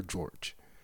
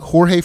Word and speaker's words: Jorge: It's spelled J-O-R-G-E, and you never know if that name is pronounced Jorge or George.